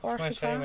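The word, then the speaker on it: Órgiva: Behalve Orgiba. Zeg ik dat goed? Or, Orgiba? – Ja, Orgiba. in één keer Orgiba.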